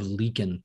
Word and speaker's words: Leakin: The bodies of Leakin Park.